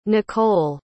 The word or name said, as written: nikkol